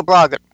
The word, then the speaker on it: Branagh: This new movie is directed by the great Kenneth Branagh, and he stars as Branagh. – Is it Branagh or Branagh? – Branagh.